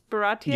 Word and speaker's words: Baratie: Is that how you say it? Baratie? – Yeah, that's how they say it in the anime and everything.